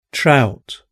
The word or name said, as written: trout